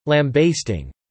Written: lambasting

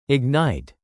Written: ignite

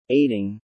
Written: aiding

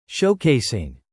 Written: showcasing

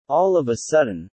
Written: all of a sudden

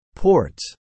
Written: ports

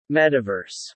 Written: metaverse

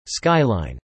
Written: skyline

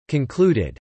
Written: concluded

